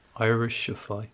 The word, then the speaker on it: Irishify: How do you pronounce that? Irishify or Irishify? – Irishify. – Irishify, isn't it?